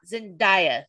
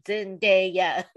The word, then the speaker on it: Zendaya: She's like, "My name is Zendaya. It is not Zendaya. Listen to me, Zendaya."